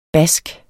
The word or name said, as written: bask